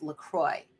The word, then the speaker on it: LaCroix: You know the water that is spelled LaCroix? No, it's LaCroix. I read this on the internet in an article. People at the company say, no, it is not LaCroix the way you would think because it is, to all appearances, a French name, but no, it is LaCroix.